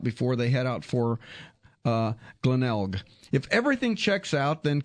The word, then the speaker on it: Glenelg: How do you pronounce Glenelg.